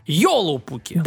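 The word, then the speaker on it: Joulupukki: Итак, в Финляндии финского Деда Мороза зовут Йолупуки. Йолупуки? Да. – Переводится... Только у них ударение всегда на первый слог. – Йолупуки.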